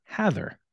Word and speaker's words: Hathor: Uh, on good authority of the internet, it is pronounced Hather. Oh, so H A T H O R. So if you, as a little device for myself, it's pronounced in a similar way to the legendary news anchor Dan Rather or the less legendary Leave It to Beaver star Jerry Mathers.